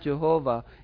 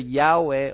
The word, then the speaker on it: Yahweh: Some mistakenly pronouncing name Jehovah. It's not Jehovah, instead of Yahweh or Yahweh. In the English one is a Jehovah, but actual pronunciation for English should be Yahweh.